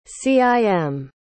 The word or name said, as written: cim